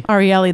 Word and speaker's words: Ariely: Some of you may have heard of Dan Ariely before. I'm not sure I'm pronouncing his last name. – Ariely, there we go. I knew I wasn't doing it right.